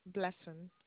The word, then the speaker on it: Blessing: Blessing. – May you spell that for me? – B-L-E-S-S-I-N-G.